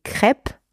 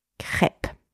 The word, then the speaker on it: crêpes: If you are talking about several crêpes, so in a plural form, des crêpes or les crêpes, you also have a silent S at the end, right? If it is in a plural. But you don't pronounce it, it is silent. Les crêpes or des crêpes.